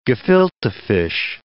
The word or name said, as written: gefilte fish